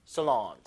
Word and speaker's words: salon: 자, 요 살롱이 강세가 표시는 안돼 있는데 뒤에 줘야 돼요. 뒤에. Salon. Salon. 자, hair 약간 써보다 약간 쓰에 가까워요. 쓰다 할 때, salon 이렇게 해보세요. Salon 영어 발음이 그래요.